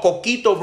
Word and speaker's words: coquito: What is that? Quiquito? No, coquito, bro.